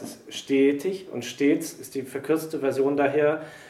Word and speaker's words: stets: which is W-A-R with at the H. In the second line of this, we get one of the exceptions to the anything with two consonants in the stem is a short open vowel sound with the word S-T-E-T-S. – Stets. – It's a long closed E, right? – Yeah.